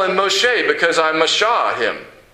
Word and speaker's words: Moshe: Moshe, that's his name in Hebrew, sounds like the word mashah, which means to draw out.